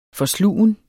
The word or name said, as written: FORSLUGEN